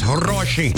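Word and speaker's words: Hroshi (гроші): This is Hroshi. That's how you say it in Ukrainian.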